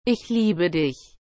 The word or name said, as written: ich liebe dich